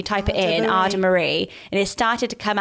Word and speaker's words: Adamaris: So it's spelled A-D-A-M-A-R-I-S. So you don't say the S, it's Ada Marie.